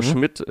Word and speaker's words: Schmyt: Also Schmidt äh, schreibt man S-C-H-M-Y-T.